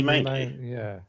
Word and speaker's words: Mainoo: Mino is still... Is it Mimo? Mino? How do you say his name? – I don't know who you're talking about. – The guy, Man United kid. You know, everyone was saying... – Bobby Mainoo. – Oh, Mainoo.